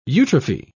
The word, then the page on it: eutrophy: British English